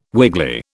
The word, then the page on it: wiggly: British English